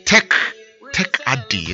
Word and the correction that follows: Tech Aid: What's the right pronunciation? Tech what? Tech Aid.